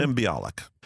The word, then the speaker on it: Mayim Bialik: Mayim Bialik. Did I pronounce that right? – Mayim Bialik. Mayim Bialik.